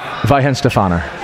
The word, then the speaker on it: Weihenstephaner: Weihenstephaner.